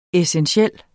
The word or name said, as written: essentiel